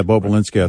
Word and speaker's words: Bobulinski: I think I'm pronouncing it correct. – Yeah, Bobulinski, I think is right.